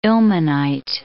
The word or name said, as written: ilmenite